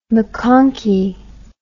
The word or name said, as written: maconchy